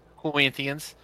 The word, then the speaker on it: Corinthians: Did I say it right? Corinthians? – Oh, you said it right. It was just like, it was like you said it with an accent. Like it was pronounced correctly.